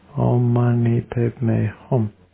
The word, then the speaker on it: Om Mani Padme Hum: Om Mani Padme Hum. Om Mani Padme Hum. Om Mani Padme Hum.